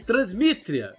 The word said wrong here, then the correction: Transnistria: Não, então, Como é que fala? Transnistria. Transnistria.